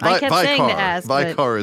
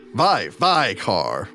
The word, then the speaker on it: Vycar: Vicar is my name. – Vicar? Really? – Vicar. You must pronounce V-Y-C-A-R.